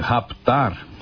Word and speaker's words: raptar: Raptar. As pessoas dizem que esse P é mudo, mas como eu expliquei, esse P não é mudo, ele é lido em português, né?